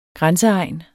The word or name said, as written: grænseegn